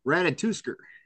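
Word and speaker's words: Ratatoskr: It's Ratatouskr.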